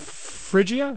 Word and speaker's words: Phrygia: And they went through the region of Phrygia. Is that how you pronounce it? – Yes.